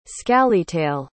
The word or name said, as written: scalytail